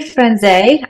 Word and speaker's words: Franze: It's technically Franze. I'm Italian, so there's supposed to be an accent on the E, but when my grandparents immigrated to Canada, they dropped it. So we just go by Franz now, but either one works.